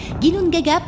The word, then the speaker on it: Ginnungagap: Un néant, un vide, un abîme que les Scandinaves nomment Ginnungagap. Vous pouvez le noter, c'est notre premier mot exotique. Ginnungagap, comme ça se prononce, à quelques lettres près.